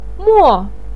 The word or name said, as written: mo4